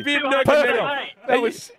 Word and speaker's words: Khabib Nurmagomedov: Khabib Nurmagomedov.